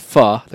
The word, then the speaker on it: pho: I made pho, also known as pho, the correct pronunciation.